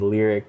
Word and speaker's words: Laric: It's pronounced as Lyric, L-A-R-I-C.